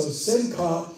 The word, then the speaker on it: simcha: Actually, simeach should really be pronounced simeach. So simcha and simeach.